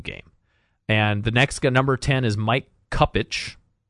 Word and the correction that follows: Kupchak: Pardon me if I'm pronouncing that wrong. – Kupchak.